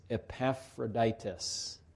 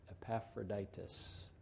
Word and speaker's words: Epaphroditus: It's Epaphroditus. Epaphroditus, okay? That's a perfectly acceptable way to say it.